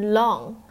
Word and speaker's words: long: OK，所以不是 lan 是吧，是 long。